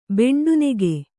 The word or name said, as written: ಬೆಂಡುನೆಗೆ